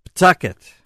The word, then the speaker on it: Pawtucket: Pawtucket.